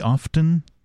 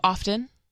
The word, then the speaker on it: often: Do you pronounce the T in often? Do you say often? – Yeah, I think I do. Often.